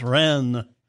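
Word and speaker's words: Reims: I was talking about the cathedral at Rheims. It's pronounced Ren.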